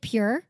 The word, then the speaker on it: pjur: P-J-U-R. – It's not you. Even, you they, it's not, yeah. It's spelled it's P-J-U-R.